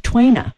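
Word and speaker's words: tuina: Good, and thank you to the acupuncturist who just called in and said it's pronounced Twaina, T-W-A-Y-N-A is the phonetic spelling that Charlie's just given me, Twaina.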